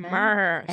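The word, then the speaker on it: Meré: Mare. M-E-R-E with an accent on the last E.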